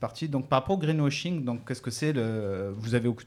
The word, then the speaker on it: greenwashing: où on va vous parler surtout de greenwashing. Rebecca, je l'ai bien prononcé là ? – Greenwashing. – Mm-hmm. C'est mieux.